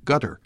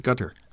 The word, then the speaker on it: Qatar: I've talked to people who've lived there who say it's much closer to gutter. – Oh, really? – Yeah, that's how they pronounce it, gutter. – Oh, but it's not really like a G. It's like halfway between a G and a C.